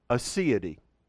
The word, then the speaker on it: aseity: It's pronounced aseity. Aseity.